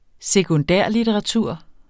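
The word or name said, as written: sekundærlitteratur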